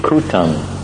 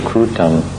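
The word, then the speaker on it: kūṭam: Then it comes to the stage of kutam, K long U T A M, kutam.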